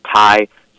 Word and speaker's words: Tighe: It's at the G-H is silent because my last name is T-I-G-H-E, but it's pronounced Ty.